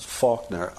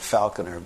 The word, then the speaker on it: Faulkner: Which he pronounces Faulkner. I called it Falconer, being, right.